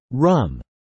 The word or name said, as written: rum